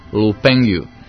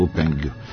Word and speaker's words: Lu Pengyu: Ja sam probao da izgovorim na srpskom, ali nešto mi ne ide. – Lu Pengyu. – Pengyu, pa dobro, to može i na srpskom, Lu Pengyu.